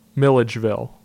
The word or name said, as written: Millegeville